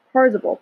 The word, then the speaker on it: Parzival: So then at one point, Parzival, apparently that's how you say it. I've been saying it wrong the whole time.